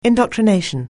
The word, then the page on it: indoctrination: anglais